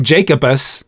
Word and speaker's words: Jacobus: His wild and quirky stories of the macabre, conjoined with, like you said earlier, Tim Jacobus.